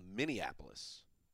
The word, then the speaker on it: Minneapolis: And finally, I think it's called Minneapolis.